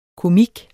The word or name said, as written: komik